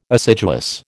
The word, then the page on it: assiduous: American English